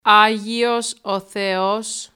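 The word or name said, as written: Άγιος ο Θεός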